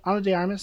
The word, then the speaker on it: Ana de Armas: Do you know how to pronounce her name? Is it Ana de Armas? – That's what I've said, and that's the only way I've heard it pronounced.